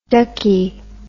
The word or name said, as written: duckey